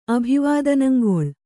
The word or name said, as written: ಅಭಿವಾದನಂಗೊಳ್